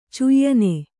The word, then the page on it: ಚಯನ: Kannada